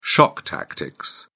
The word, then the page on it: shock tactics: British English